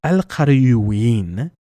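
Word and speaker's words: al-Qarawiyyin: It's Al-Qariyuin, which is a bit of a tongue twister because you're making a ween sound.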